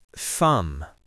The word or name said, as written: thumb